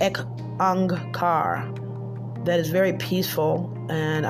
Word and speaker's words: Ek Ang Kar: It's spelled EK space ONG space car KAUR Ek Ang Kar, and it means one God, okay, one creation.